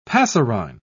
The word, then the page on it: passerine: British English